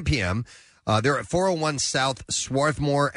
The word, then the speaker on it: Swarthmore: Swarthmore. – Swarthmore. Yeah. All right. Yeah. There's an R in there, you know. – I know. – But they skip over that.